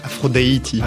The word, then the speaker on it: Afro Deity: C'est sur le label Paris DJ, Grande Fabao et Afro Diet, c'est comme ça qu'on le prononce ? – Afro Deity.